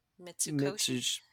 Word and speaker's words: Mitsukoshi: Mitsukoshi.